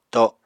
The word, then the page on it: to: Apatani